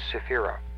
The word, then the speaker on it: Sephira: O-T-H is a plural ending. It means many, several. The singular spelling of the word Sephirot is Sephira. S-E-P-H-I-R-A, R-A-H, I guess. Sephira. That's the singular for this word. Each circle in this diagram is a Sephira.